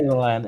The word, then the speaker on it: Island: Island. Iceland. Não, não tem o S. Island. O S é, é silence, como fala, teacher, certo? – Island. Silent S.